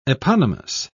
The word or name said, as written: eponymous